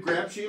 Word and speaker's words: Grapshi: Why did you, David Grapshi, am I saying his name right? – Mm-hmm.